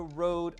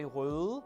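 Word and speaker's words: Røde: Actually, here in Denmark, we would say Rode because that is actually how it's spelt. It's using one of those letters which is, you know, quintessentially Danish, but apparently the company that makes it is from Australia. So let's go with Rode for for the duration of the video.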